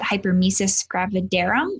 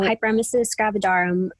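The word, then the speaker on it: hyperemesis gravidarum: No, and May obviously is also May 14th, you said, is hopefully I do not butcher this, but hypermesis gravidarum, is that correct? – Yeah. Yep. So hypermesis gravidarum is a pregnancy condition where the woman suffers from debilitating vomiting, nausea, mm-hmm.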